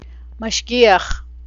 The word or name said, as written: mashgiach